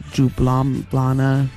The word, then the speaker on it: Ljubljana: That's Slovenian hardcore from the hardcore Ljubljana, is how you say it.